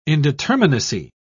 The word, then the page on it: indeterminacy: British English